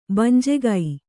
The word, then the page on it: ಭಂಜಕ: bhanjaka